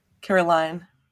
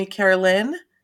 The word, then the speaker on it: Caroline: And her name kind of rhymes with mine, because my name is, well, Caroline. – Yeah, you're Caroline, never Carolyn, right? – Well, actually, so it's spelled Caroline, but my entire life, my mother called me Carolyn.